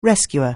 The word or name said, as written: rescuer